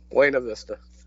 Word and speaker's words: Buena Vista: Buena Vista.